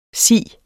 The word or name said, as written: siens